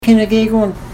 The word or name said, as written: kina gegoon